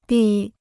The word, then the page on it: 闭: bì